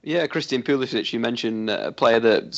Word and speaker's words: Pulisic: They've got that young talent, Pulisic. I think I've said that wrong. – You haven't. It's perfect.